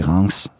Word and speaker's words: Reims: Så sidder man og tænker, for det staves R-E-I-M-S. – Ja. – Men det udtales Reims. – Mm-hmm. – Altså ligesom France uden F.